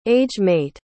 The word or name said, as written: age-mate